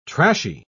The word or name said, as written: trashy